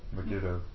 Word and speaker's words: Megiddo: Megiddo.